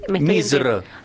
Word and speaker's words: Misr: Pero es eso, como te digo, hay muchos lugares que han sido, por ejemplo, Egipto, en la lengua original, ¿cómo se dice? Misr. Y nosotros nada que ver, decimos Egipto. – ¿Cómo se dice, perdón? – Misr.